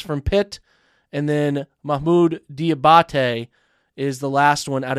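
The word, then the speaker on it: Diabaté: I think Mahmoud Diabite is, actually might be pronounced, I could be saying that wrong. It's Diabate. Sorry, not Diabite. So it's Diabate. Mahmoud Diabate is the name pronunciation.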